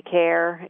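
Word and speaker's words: Kaehr: I've got Instagram at Shelley Care, if they can spell my last name.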